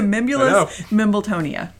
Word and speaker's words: Mimbulus mimbletonia: It's a Mimbulus Mimbletonia.